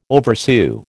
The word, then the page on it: oversew: American English